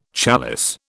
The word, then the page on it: challis: British English